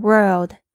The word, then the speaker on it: world: The world 就是说这个世界，注意一下你们 world 的发音是一个 world 哦，所以你舌头呢尽量要记得卷舌回来，要发出这个 l 的这个 o 的这个声音。